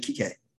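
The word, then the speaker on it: Kiké: Actually, the player preferred pronunciation is actually Kike. He actually prefers Kike. So Bjorn, that wasn't very that's kind of problematic, Bjorn, to call him Enrique.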